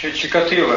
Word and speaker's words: Чикатило: Чикатило, да, Чикатило.